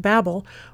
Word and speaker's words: Babel: From that, we got into talking about the Tower of Babel, which apparently is supposed to be more closely pronounced Bab-el, because it means gateway to God.